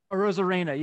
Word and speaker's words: Arozarena: Arozarena.